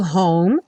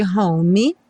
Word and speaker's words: home: E o home, sem pronunciar o E, nada de home. A gente também já viu várias vezes esses Es, enfim, de palavras que não são pronunciados.